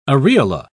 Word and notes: areola: British English

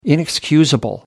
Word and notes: inexcusable: American English

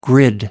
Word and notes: grid: American English

